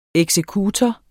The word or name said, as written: eksekutor